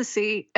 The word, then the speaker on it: See: Twitter, I'm at Melissa C. And this has been an extremely just long battle of my life because of my last name is not just the letter C. It's spelled S-E-E. So it's at M-E-L-I-S-S-A-S-E-E.